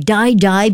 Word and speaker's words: Dai Dai: Die Die, basically it is spelled as D-A-I-D-A-I, and it means let's go in Italian.